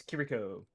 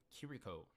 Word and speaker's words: Kiriko: How do you pronounce his name? – I've only saw it once. Let me see. It is Kiriko. – Kiriko.